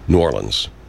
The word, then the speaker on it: New Orleans: Most people can't say New Orleans, I just said it wrong. It's New Orleans. New Orleans. New Orleans. That's it. That's how you pronounce it. New Orleans. Not New Orleans.